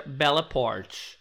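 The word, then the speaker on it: Bella Poarch: Tá, aparentemente se pronuncia Bella Port. Se escreve P-O-A-R-C-H, mas a pronúncia é Port.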